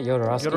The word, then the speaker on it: Jodorowsky: That movie is El Topo, directed by Alejandro Jodorowsky. How do you say his last name? – I think that's right, Jodorowsky.